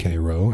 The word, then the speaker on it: Cairo: She hunched over a wheelbarrow as her friend Joe Bell and I helped her look for rocks to seal Cairo, pronounced Cairo after Cairo, Illinois, beneath his little mound of earth.